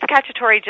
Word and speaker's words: Cacciatore: It's Cacciatore, just like the Italian food.